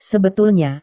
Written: sebetulnya

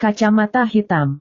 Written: kacamata hitam

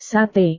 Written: sate

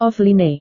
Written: offline

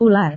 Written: ular

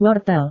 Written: wortel

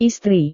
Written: istri

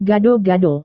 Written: gado-gado

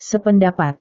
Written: sependapat

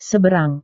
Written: seberang

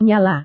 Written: nyala

